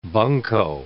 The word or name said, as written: bunco